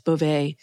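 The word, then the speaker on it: Bove: Acting Deputy Attorney General Emil Beauvais, we've now learned how to pronounce his name, it's Beauvais,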